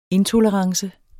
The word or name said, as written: intolerance